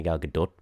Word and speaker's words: Gal Gadot: No offense to Gal Gadot. That's how you say her name. I had to look that up. No offense to Gal Gadot, but offense to Gal Gadot.